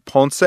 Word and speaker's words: Ponce: I'm at Anthony Ponce on Facebook. Last name is spelled P-O-N-C-E.